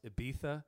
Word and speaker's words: Ibiza: So, like, you know how Ibiza is spelled like I just said it, but mm-hmm. it's actually pronounced Ibiza. – Mm-hmm. – Oh yeah. – He oh, you mean like the Castilian? Yeah, that with the lisp because yeah.